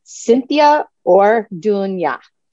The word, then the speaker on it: Cynthia Orduna: So, Cynthia Orduna.